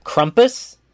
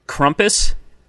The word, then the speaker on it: Krampus: Krampus. – Krampus.